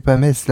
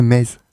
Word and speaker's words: Metz: C'est pas Metz, la prononciation, c'est Metz. Moi, je l'ai toujours prononcé Metz. Ouais, je sais pas. C'est Metz. C'est Metz. Ah, d'accord, ok. Non, parce qu'il y a beaucoup qui disent Metz. Non, mais c'est bizarre. Ça s'écrit Metz, mais ça ne se prononce pas pareil.